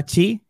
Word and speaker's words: Qi: El nuevo estándar Chi, en este caso el Chi 2, o tal como lo pronunciamos aquí, QI 2.